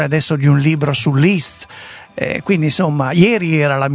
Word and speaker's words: Liszt: Sarebbe Liszt, molti sbagliano, però io sono andato a controllare la lingua ungherese, effettivamente è Liszt, molto semplicemente.